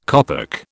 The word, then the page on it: kopek: British English